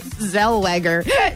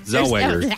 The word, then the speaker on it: Zellweger: Renee Zellweger? – Yes. – Am I saying that wrong? – Yes. – How is it? How do you say it? – Zellweger. There's no N. – Zellweger.